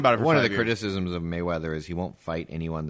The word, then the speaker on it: Mayweather: Is it Mayweather or Merryweather? – Mayweather. Big boxing fan, are you? – No, not huge, man.